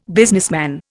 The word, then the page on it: businessman: Indian